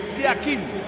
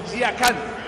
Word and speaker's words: Yakin: En el suizo se pronuncia así, Murat Yakin. En turco, Yakan. Cambia la última parte de su apellido.